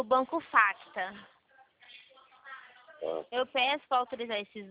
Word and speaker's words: Facta: É, o Facta, ele aparece no aplicativo como Facta Financeiro. O C é mudo. F-A-C-T-A.